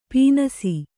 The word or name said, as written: ಪೀನಾಸಿ